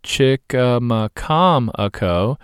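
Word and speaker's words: Chicamacomico: Chickamaucomico, pronounced Chickamaucomico, is a 400-year-old Indian name for a famous place on Hatteras Island.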